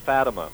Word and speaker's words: Fatima: Yeah, that's pronounced Fatima. – Fatima, there you go.